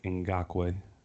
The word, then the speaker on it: Ngakoue: it's Yannick Ngakoue.